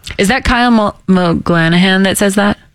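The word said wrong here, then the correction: MacLachlan: Yeah, I mean, you almost said his name right, but yeah. – McClanahan? – McLaughlin. – McLaughlin. – Yeah.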